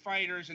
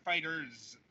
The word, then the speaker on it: FighterZ: All right, just to clarify things, it's pronounced FighterZ and not FighterZ.